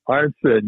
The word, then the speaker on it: Arnson: Okay, next up is Coach Tony Arston. – Oh, come on. You can't put him in yet. – I said his name wrong. – Arnson, yeah.